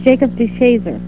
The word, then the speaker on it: Jacob DeShazer: And Carol's father, and his name was Jacob DeShazer, is it? That's pronounced. – Yes, it's Jacob DeShazer.